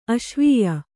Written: ಅಸೇವ್ಯ

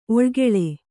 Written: ಒಳ್ಗಾಲ

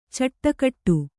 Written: ಚಿಟುಕಿಡು

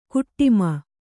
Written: ಕಾಟಿಮೆ

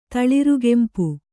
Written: ತಳಿರುಗೆಂಪು

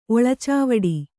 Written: ಒಳಚಾವಡಿ

